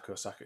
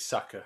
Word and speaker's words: Saka: Saka. Yeah, it's Saka.